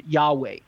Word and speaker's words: Yahweh: The bottom line is that most Hebrew scholars generally reject this attempted pronunciation of God's holy name and favor the more common Yahweh.